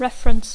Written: reference

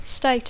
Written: state

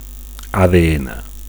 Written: ADN

